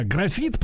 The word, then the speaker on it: графит: Э, значит, а вот по, по графиту, да, графит, правильно я произношу, не графен, да, а графит. Да.